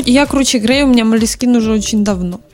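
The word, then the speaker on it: Moleskine: Я не знаю, я вижу Малескин какой-то, ишь, Малескин. Я тебе расскажу. Это французское слово, ударение на последнее слово.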